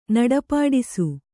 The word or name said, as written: ನಡಪಾಡಿಸು